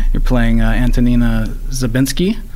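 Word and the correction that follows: Żabińska: Well, you say when it's the woman by herself, you say Zabinska. – Zabinska, okay.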